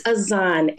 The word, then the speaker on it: Az'an: It's Az'an, A-Z-A-N.